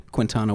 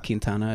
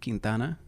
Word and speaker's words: Quintana: Did I pronounce your last name correct? Or is it Quintana? – Yeah, that's the Americanized pronunciation. So Quintana works. – But it's Quintana. – Quintana is the, it's Puerto Rican by descent. Quintana is how you would hear it said in my dad's house.